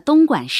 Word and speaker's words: 东莞: 晚，莞尔一笑；管，位于中国广东省的东莞市；关，关草、水葱一类的植物，可以用来编织席子。